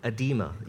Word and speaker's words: edema: Edema. – Edema, yeah, okay.